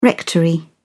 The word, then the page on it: rectory: British English